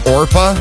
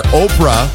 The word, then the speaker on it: Orpah: Did you know Oprah's given name was actually Orpa, O-R-P-A-H? Relatives kept mispronouncing it. They kept calling her Oprah, and I guess it just stuck after all those years.